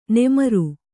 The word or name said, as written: ನಮ್ರ